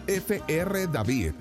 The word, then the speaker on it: F.R. David: F.R. David lo pronuncio en español porque él es francés.